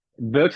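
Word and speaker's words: Beuc: Et du coup, ça s'appelle, alors vous prenez bien note, ça s'appelle l'Osteria del Buck. Buck, ça s'écrit B-E-U-C, c'est du lombard.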